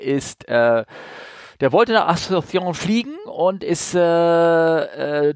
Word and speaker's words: Asunción: Wie spricht man das aus? Kannst du das aussprechen? Asunción. Asunción, okay.